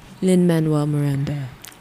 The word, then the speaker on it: Lin-Manuel Miranda: Saying his name wrong. – What's his name? – Lin-Manuel. – Lin-Manuel Miranda.